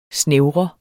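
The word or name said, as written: SNÆVRER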